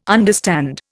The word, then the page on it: understand: Indian